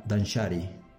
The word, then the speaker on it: Danshari: Esto me hizo investigar mucho de ver, por ejemplo, como en Japón, tiene una palabra que, se refiere al minimalismo que se pronuncia Danshari. Y Danshari se descompone en Dan, que es rechazar, Sha, que es descartar, Iri, que es separar.